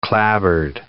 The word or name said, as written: clabbered